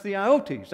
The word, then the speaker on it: theiotes: This particular word, though, is pronounced theiotes, that is T-H-E-I. O-T-E-S.